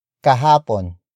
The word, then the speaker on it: kahapon: The next word is kahapon. Kahapon. Kahapon. Kahapon is spelled as K-A-H-A-P-O-N.